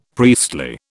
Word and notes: priestly: British English

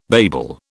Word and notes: babel: British English

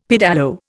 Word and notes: pedalo: Indian

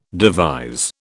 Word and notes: devise: British English

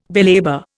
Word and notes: belabour: Indian

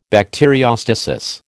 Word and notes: bacteriostasis: American English

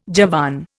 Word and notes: Javan: Indian

